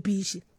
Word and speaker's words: beaches: Every time I try to pronounce this word, my students just laugh at me as if I do not pronounce it correctly. The beaches. – There you go. Beaches.